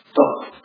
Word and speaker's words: tov: La palabra bueno, buena, en hebreo se dice tov, tov, T-O, V, diríamos nosotros.